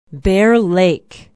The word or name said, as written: bear lake